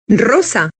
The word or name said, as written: rosa